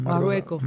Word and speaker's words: Marruecos: Marruecos.